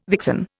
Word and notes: vixen: Indian

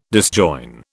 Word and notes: disjoin: British English

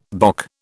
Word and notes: bock: British English

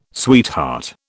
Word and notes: sweetheart: British English

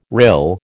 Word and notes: rill: American English

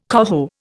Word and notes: coho: Indian

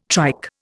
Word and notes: trike: Indian

